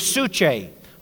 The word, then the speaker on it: psuche: First one in the Greek is suche. A lot of the time when you read in the New Testament, you see the word life, and the Greek word is suche. It's spelled P-S-U-C-H-E. I just wrote it on the blackboard there for you. P-S-U-C-H-E.